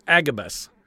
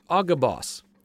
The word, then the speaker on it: Agabus: We've heard him as Agabus. But I would like to say his name for how it was, best I can tell, pronounced then. It's Agabus.